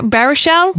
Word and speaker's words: Baruchel: Baruchel? – Yes, there you go. Good job. – Jay Baruchel, who we love in Goon, she said, really pulled off the New Englander's accent.